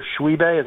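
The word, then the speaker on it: Tshiebwe: Frederick, and they've got Oscar Shuibe. Is that how you pronounce it? – Yep.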